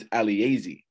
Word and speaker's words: Alaezi: He's telling me, you know, and and he said, I'm actually Nigerian, and in Nigerian culture, you pronounce every vowel. So he told me it's Alaezi, right? That's why his name is Alaezi.